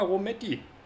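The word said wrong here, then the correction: Birra Moretti: Bira Moretti, l'Authentica.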